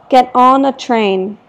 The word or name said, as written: get on a train